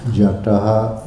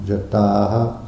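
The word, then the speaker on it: jātāḥ: Jataha. – Jataha. – Excuse me, I said that wrong. Jataha.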